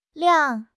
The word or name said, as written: liang3